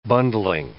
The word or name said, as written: bundling